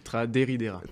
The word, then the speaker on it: Traderidera: Vous êtes secrétaire de l'association Traderidera, c'est bien comme ça qu'il faut prononcer. – C'est Traderidera. – Traderidera, ouais. Voilà, comme dans la chanson.